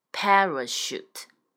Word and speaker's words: parachute: 叫做 parachute，重音是在 pa 上面， parachute 叫降落伞。